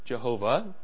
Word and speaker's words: Jehovah: Depending on how you change Hebrew letters into English, that's either J-H-V-H, which we pronounce out loud, Jehovah, or YHWH, which we pronounce out loud, Yahweh.